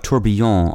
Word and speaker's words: tourbillon: Today's word is tourbillon, spelled T-O-U-R-B-I-L-L-I-O-N. Tourbillon also has a variant spelling and pronunciation that is closer to the French, spelled T-O-U-R-B-I-L-L-O-N and pronounced tourbillon.